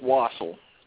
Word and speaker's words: Wosel: It's Wosel.